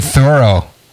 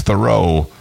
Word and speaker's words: Thoreau: Henry David Thoreau. – Thoreau. It's Thoreau.